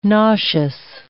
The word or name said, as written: nauseous